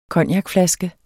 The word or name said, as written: cognacflaske